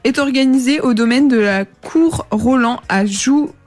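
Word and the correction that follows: Jouy-en-Josas: En Josas, je sais pas si je le dis bien, c'est dans le 78. – Je crois que c'est Jouy en Josas.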